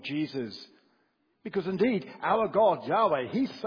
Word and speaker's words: Yahweh: The name in Hebrew, which we pronounce as Yahweh.